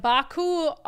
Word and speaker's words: Baku: All right, so as I mentioned earlier, Governor Inslee was in Baku, Azerbaijan. Why are you laughing? – Well done. – I don't know if it's pronounced Baku, but I just assumed. – I wouldn't. I'm complimenting you.